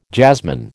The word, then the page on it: jasmine: American English